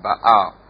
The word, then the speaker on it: Baal: The Bible says that when Ahab married her, she came to Israel with 850 prophets of, we call it Baal, but I found out that the correct pronunciation is Baal, which simply means husband.